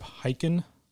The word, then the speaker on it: Hyken: But the book that you've challenged us all to read, it is called Amaze Every Customer Every Time by Shep Hyken. – Nice pronunciation on the name.